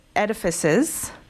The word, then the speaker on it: edifices: So, because in your book, you have a whole section on dreams, and there's also, there's not just dreams, there are edifices. Am I pronouncing that correctly? – That's correct.